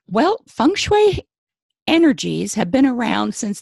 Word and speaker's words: feng shui: Some people have a hard time pronouncing it because they look at the letters and say, wait a minute, isn't there an E in there? Yes, but it's pronounced feng and shui.